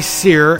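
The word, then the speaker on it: Cyr: Sear and the Midnight Men. That's C-Y-R. It rhymes with beer.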